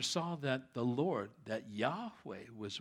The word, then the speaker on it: Yahweh: It translates the Hebrew Yahweh, Y-H-W-H. God's name is not Jehovah. They don't have a J or a V in their alphabet. It'd be very difficult to pronounce that for a Hebrew.